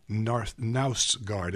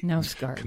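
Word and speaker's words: Knausgård: It's Carl Ove Knausgard. It's Knausgard.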